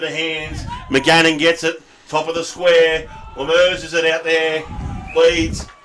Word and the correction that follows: Lammers: Jacob Lammers. – Lemers. Lammers. Okay. Had the right bloke. I just didn't get the pronunciation spot on.